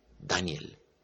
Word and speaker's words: Daniel: Daniel salió me informó él pronunciando el nombre de su hijo con el acento en la primera sílaba, Daniel, como si me estuviera corrigiendo.